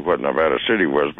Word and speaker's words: Nevada: Is that how you say it, or is it not Nevada there? – Nevada. – Yeah. I think I... it is Nevada.